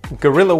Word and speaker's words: guerrilla: So it's a different word from the word gorilla the ape, but it's pronounced the same way in English. So for example, guerrilla warfare can be incredibly effective.